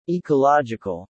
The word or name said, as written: ecological